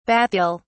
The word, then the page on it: bathyal: British English